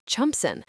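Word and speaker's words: Chelmondiston: Barnoldswick becomes Barlick, Chulminston becomes Chumpson.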